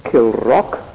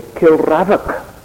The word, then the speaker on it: Kilravock: And the castle, by the way, was called Kilrock Castle. Although you don't spell it like that. If you pronounced it the way it's spelled, you would have to say Kilravock. But it's called Kilrock Castle.